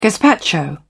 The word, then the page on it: gazpacho: British English